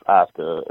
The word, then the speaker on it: Asuka: Why can't I say her name Asuka. – Right? I always say her name wrong. Asuka. I call it. I try to say it from how it's. – We used to call it the WWE way. Yes, it's Asuka.